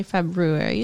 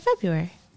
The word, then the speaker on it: February: But you don't say February. You say February. – Why? – Because English is a ridiculous language with all kinds of silent letters in the words.